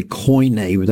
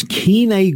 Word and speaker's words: Koine: A lot of people say Koine, but that's academic pronunciation. It's Koine Greek.